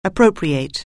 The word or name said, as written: appropriate